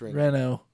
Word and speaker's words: Renault: It's pronounced Renault.